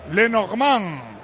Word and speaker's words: Lenormand: Lenormand.